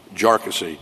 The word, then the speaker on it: Jarkesy: And by the way, it's pronounced Jarkesy uh, not, not a number of other ways that, it, that it's been pronounced by, by many.